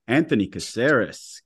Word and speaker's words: Anthony Caceres: Anthony Caceres.